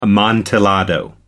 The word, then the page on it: amontillado: British English